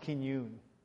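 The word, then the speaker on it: Kinyoun: His name was Dr. Joseph, I think it's pronounced Kinyoon.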